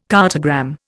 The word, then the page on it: cartogram: Indian